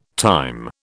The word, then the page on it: thyme: British English